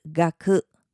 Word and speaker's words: がく: 第二种是嘎克，嘎克，嘎克。